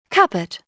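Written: cupboard

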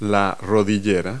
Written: kneepad

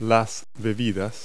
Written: beverage

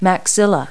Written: maxilla

